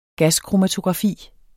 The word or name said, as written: gaskromatografi